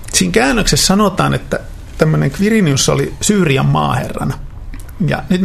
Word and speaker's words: Quirinius: Se on ihan niin kuin KV, Quirinius, hän äänsi itse nimensä. – Mutta miksi me sanomme Kyrenius?